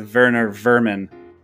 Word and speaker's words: Werner Werman: Next is Werner Werman, although I think it's supposed to be pronounced with a little bit of a German accent, so it's Werner Vermin.